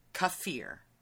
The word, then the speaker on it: kefir: K-U-H, like uh, Kuffer. no, ka, fear. Kafir. Kafir. – And the and the emphasis is on the fear. Like F E A R, fear.